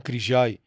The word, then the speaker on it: Крижай: А, Боян Крижай, който ние му казваме Крижай, но нали словенците имат малко различна система на ударенията.